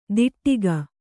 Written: ದಿಟ್ಟಿಗ

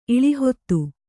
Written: ಇಳಿಹೊತ್ತು

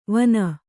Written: ವಿನಾ

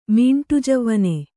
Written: ಮೀಂಟುಜವ್ವನೆ

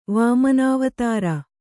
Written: ವಾಮನಾವತಾರ

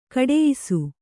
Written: ಘಟಿಯಿಸು

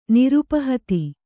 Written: ನಿರುಪಹತಿ

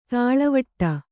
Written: ದೂಳುವಡೆ

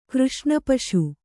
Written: ಕೃಷ್ಣಪಶು

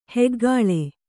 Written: ಹುಗುಳು

